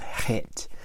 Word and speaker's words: Het: Spelled C-H-E-S, but pronounced Het.